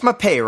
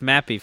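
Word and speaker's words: Mapei: You know, speaking of Mappé, it's Mappé, right? Like, I always, I don't know how to pronounce that team name. Either. But it's Mappy for me. So, Mappy.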